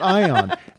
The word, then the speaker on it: ion: And Sean said, well, I had pronounced it eon engine because in Greece, they say eon, and not ion.